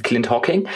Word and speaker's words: Clint Hocking: von einem Menschen namens Clint Hocking, richtig? Richtig. Habe ich richtig ausgesprochen? Ja, Clint Hawking,